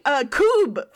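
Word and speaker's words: kubb: I got to play Kub for the first time, which we've talked about. It's the it's spelled K-U-B-B. I think Kub is the official pronunciation. I think it's like Norwegian or something.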